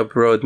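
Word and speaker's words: bröd: And I guess fika broad means fika bread. – Yes. – Is that how you say it? – Broad. – Oh, yeah. Fika broad. Okay.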